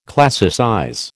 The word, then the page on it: classicize: American English